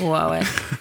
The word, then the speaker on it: Warwick: Okay, so Warwick or Warwick? – Warwick. – I don't know if I can pronounce it that way.